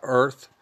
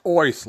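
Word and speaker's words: Oerth: The new Oyth Journal, not Earth. Gygax wrote that it's pronounced Oyth, like Curly from the Three Stooges.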